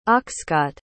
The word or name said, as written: oxcart